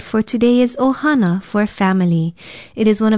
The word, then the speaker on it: ohana: It is spelled Okina, O-H-A-N-A, ohana.